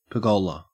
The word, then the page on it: pergola: Australian English